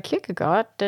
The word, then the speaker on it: Kierkegaard: Also ich glaube, ich habe mal, also das, man sagt nicht Kirke, sondern eher so Kirche. Also so ein bisschen angehaucht, aber vor allem Goa. – Goa, ja, genau. Also Kierkegaard.